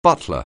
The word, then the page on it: butler: anglais